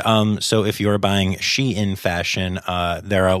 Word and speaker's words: Shein: And um, apparently uh, it is pronounced Shein. Um, even though it is spelled kind of like shine, it is "she in fashion.